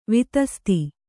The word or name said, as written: ವಿತಸ್ತಿ